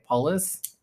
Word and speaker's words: Polis: Okay, so uh, I wrote down uh, Colorado Governor Jared uh, Pulse. How do you say it? Polis? Polis, yeah.